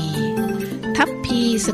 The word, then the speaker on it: ทัพพี: ทัพพีสะกดด้วยทอทหารไม้หานากาศพอพานพอพานสารีอ่านว่าทัพพีทัพ